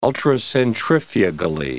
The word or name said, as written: ultracentrifugally